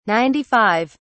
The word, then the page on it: 95: British English